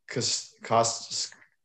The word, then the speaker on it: Castellanos: Nick Castellanos.